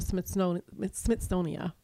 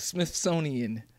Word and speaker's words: Smithsonian: Did I say that right? – No, but I think everybody knows. – Drink. Say it. You know what I did? I'm going to tell myself. – Smithsonian. Yes. There's an N at the end of the word.